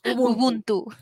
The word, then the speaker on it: Ubuntu: Ubuntu, Ubuntu, ¿lo dije bien? – Ubuntu. Exacto, así, súper.